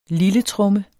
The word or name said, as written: lilletromme